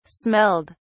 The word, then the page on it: smelled: British English